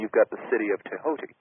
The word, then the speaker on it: Thoth: And it dawned on me while I was there, the Hermes Trismegistus had, of course, an Egyptian name spelled T-H-O-T-H. Many people pronounce this Thoth or Toth. But actually, the proper pronunciation is Tehoti.